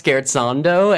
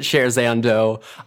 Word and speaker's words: Scherzando: So the game is pronounced Scherzando, and if you pronounce it Scherzando, we will gently rib you for it, but otherwise let it go, because we understand that it's Italian and it's a music word, and that's fine.